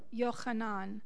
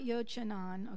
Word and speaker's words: Yohanan: And let's all say his name together, Yohanan. It's not Yochanan, okay? Yohanan. Yohanan.